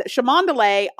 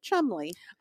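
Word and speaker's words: Cholmondeley: He is the seventh Marquess of Chamondele, although they pronounce it Chumley.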